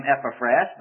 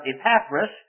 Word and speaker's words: Epaphras: Epaphras, you might pronounce his name Epaphras, but Epaphras was